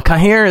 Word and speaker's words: Cahir: Kaida or whatever his name is. Well, Cahir is the official pronunciation.